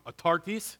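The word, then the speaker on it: Autarkis (αὐτάρκης): Autarkis. Is that how you say it, Darren? Am I close? Pretty good.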